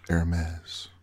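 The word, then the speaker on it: Hermès: In the fashion world, it's pronounced Hermes.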